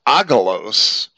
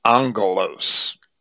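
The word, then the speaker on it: angelos (ἄγγελος): And where you see a double G, the lexicons inform you that it should be pronounced as an NG. So that Greek word that I would pronounce agelos, A-G-G-E-L-O-S, which is an angel, a messenger, that's why it's angel in English, because the Greeks would pronounce it angelos, right?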